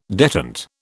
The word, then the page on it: detent: British English